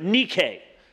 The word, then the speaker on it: Nike: The word is Nike, or in the Greek, you would pronounce it Nike.